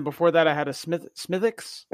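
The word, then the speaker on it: Smithwick's: They call it Smithix. – No, that's right.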